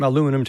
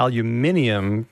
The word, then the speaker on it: aluminium: What the British did was they decided, well, let's change it from aluminum to aluminium to mix with, to make it sound like other elements like strontium and borium. – And helium. – Barium, helium. Mm. But the Americans are actually doing it the original way. Not saying that's right, but the British are the innovators here.